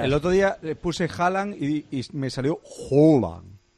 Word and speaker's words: Haaland: Es Haaland. – Sí, sí. Odegaard Haaland.